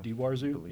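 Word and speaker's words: Die Warzau: Go Fight is a industrial band out of Chicago led by Jim Marcus, who was also in Daiwarzu, I think is how you say it. – Daiwarzu. – Daiwarzu, yeah. So.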